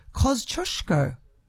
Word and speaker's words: Kosciuszko: He says, as a person of Polish heritage, it was great to hear Tristan from the Bomb give Kosciuszko, that's how I'm pronouncing it, its correct pronunciation, Kosciuszko.